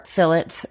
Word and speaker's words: fillet: We're doing 4 fillets that are 5 to 6 ounces. And I know I say fillet, it is because I'm Canadian and we use the British pronunciation.